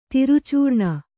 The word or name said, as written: ದುರಾಚರಣೆ